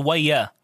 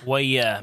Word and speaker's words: Wayah: It's called Waya. – Waya? – Waya means like, you know. – Can you spell that? – W-A-Y-A-H. – W-A-Y-A-H.